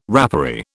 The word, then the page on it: rapparee: British English